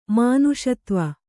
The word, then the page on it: ಮುನಿಸತ್ತ್ವ: muni sattva